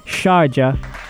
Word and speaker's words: Sharjah: You've got Sharjah, and yes, I said it right, Sharjah, doing big things in the Arabian Gulf League, and I'm so proud.